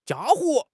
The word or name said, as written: 家伙